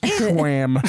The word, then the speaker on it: Schwan: I feel like Schwan is a scam. – Mm-hmm. It might mm-hmm. be, but mm-hmm. it didn't taste like a scam. – It's pronounced Schwam.